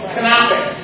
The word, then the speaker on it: Knoppix: There's a great version of Linux called Knoppix, and they pronounce the K.